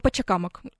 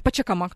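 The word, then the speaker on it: Pachacámac: Э, ну, вот, э, в частности, самые известные примеры — это Тиуанако э, рядом с озером Титикака в Боливии и Пачакамак, или Пачакамак, тут можно разные варианты ударения услышать.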